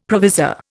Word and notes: provisor: Indian

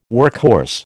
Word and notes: workhorse: American English

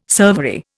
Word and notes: servery: Indian

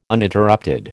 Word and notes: Uninterrupted: American English

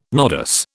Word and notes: nodus: British English

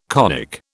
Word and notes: conic: British English

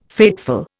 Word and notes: fateful: Indian

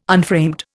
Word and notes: unframed: Indian

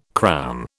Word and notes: crown: British English

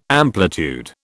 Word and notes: amplitude: British English

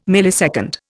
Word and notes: millisecond: Indian

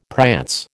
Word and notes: prance: American English

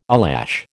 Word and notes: eyelash: American English

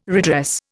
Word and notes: redress: Indian

